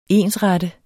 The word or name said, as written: ensrette